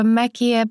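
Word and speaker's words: Mäki: Tak Sasínek je jednoduchý a Meky je prostě něco mezi A a E. A je to těžký asi to vyslovit pro ty, co neumí přehlásku, ale myslím si, že jsem v pohodě, i když je to jako Meky.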